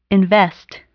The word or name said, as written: invest